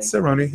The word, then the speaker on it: Cerrone: UFC star Conor McGregor will make his return in January against Donald Cowboy Cerrone. Am I saying that correctly? – Cerrone, yep.